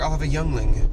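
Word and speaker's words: Yuengling: Let me know specifically why it's pronounced Yingling.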